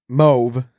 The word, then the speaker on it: mauve: So mauve is mauve. Yeah, mauve. It once rhymed with stove, but now the AU is son of. So it's mauve.